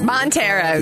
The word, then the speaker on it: Montero: Lil Nas X, Montero. Am I saying it right? – Montero. Yeah, good job.